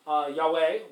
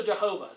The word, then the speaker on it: Yahweh: And actually, there are two different accepted pronunciations of Yahweh. Yahweh, which looks correct, but also Jehovah.